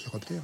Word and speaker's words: chiroptère: Les chiroptères, hein ? – Oui, je pense que c'est ça. Vas-y. – Les chiroptères. – L'ordre des chiroptères, ça s'écrit C-H-I. Et ça se prononce bien chiroptère.